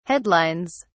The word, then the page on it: headlines: British English